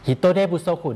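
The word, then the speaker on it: ฮิ โ ตเดบุโ ซคุ (人手不足): บุโซคุนั้นมาจากคำว่าฟุโซคุนะครับตอนที่อยู่คำเดียวๆเนี่ยนะครับออกเสียงว่าฟุโซคุแต่ว่าพอมาผสมกับคำอื่นเนี่ยนะครับก็มีการเปลี่ยนแปลงเสียงอ่านนิดหน่อยอ่านออกเสียงว่าบุโซคุครับฮิโตเดบุโซคุ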